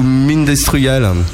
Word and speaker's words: Mind Destroyal: Alors, on dit Mind Destroyal ou Mind Destroyal? – Mind Destroyal. – Mind Destroyal? D'accord, donc on prononce ça en anglaise, mais ça chante en French.